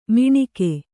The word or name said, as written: ಮಿಣಿಕೆ